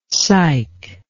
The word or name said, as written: cyke